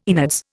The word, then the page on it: innards: Indian